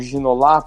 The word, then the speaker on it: Ginola: O, o ginola ou ginolar, né? Os franceses certamente falam ginolar porque falam a, a, sílaba tônica sempre no último, na última sílaba.